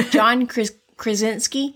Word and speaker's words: John Krasinski: Uh, John Krasinski, am yeah, I saying that right? – Yeah.